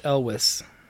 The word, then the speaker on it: Elwes: And it looks like it's pronounced Elwes. – Elwes? So we were close. If not right on the money.